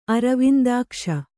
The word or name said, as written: ಅರವಿಂದಾಕ್ಷ